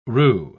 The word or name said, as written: roux